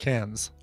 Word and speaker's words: Cairns: My favorite example is the city in Australia, Cairns, which, because we pronounce the R here, we pronounce Cairns. – Cairns, but I would say Cairns, yeah. – Yeah, but in Australia, it's pronounced Cairns.